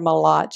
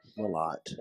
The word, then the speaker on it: Mlott: How do you say it again? – She won't. She's a great girl. Jennifer Mlott. She's a Mlott. – Yes.